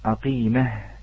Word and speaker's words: عقيمه: نعم، فإذا أخوتي الكرام الحروف الباقية جمعوها العلماء في قولهم أبغي حجك وخف عقيمه، أبغي حجك وخف عقيمه، وهذه تلفظ بالهاء في النهاية ولا تلفظ بالتاء، ترسم تاء ولكن تلفظ هاء لكي لا تدخل التاء في الموضعين، التاء معنا في قوله في قول الجمزوري، هي التاء موجودة في الإدغام وليس في الإظهار، فهنا هاء. نعم، عقيمه بالهاء.